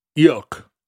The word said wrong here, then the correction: Eochaidh: Or, yeah. – No, you said Oka. – Oka. It's pronounced Oka. – That sounds right. – Yeah, Oka. – All the DH stuff goes away.